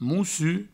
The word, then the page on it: Mossur: Moussu